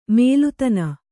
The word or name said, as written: ಮೇಲುತನ